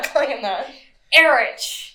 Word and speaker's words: Erich: It's spelled E R I C H. Mm-hmm. That's disgusting. Erich. His name is Erich.